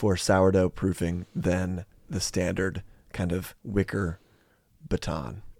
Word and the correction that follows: banneton: banneton. Banneton. Uh, you always butcher that one.